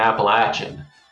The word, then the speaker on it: Appalachian: It is a pleasure to be with a host who pronounces correctly the hard CH in Appalachian and doesn't try to come off like a snob, but accidentally pronounce it incorrectly by softening it into a sh sound.